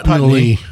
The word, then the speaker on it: Pulteney: It's pronounced Pulteney. Spelled Pulteney.